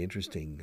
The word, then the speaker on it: interesting: Pero nos comemos una sílaba. No solemos decir interesting, cuatro sílabas. Solemos decir interesting. Interestante, literalmente. Interesting. Interesting, aunque los tejanos decimos, bueno, yo no, pero dicen interesting, como si la T no estuviera.